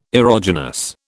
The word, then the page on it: erogenous: British English